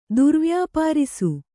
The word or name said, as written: ದುರ್ವ್ಯಾಪಾರಿಸು